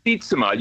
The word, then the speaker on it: Sietsema: Washington Post's Tom Sietsema, or Sietsema. Good Lord, how do you say it? We always screw it up, Tom. – Sietsema. You got it right the first time.